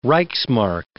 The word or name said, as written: reichsmark